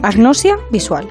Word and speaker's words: agnosia: Lo he dicho bien, agnosia visual. Es correcto.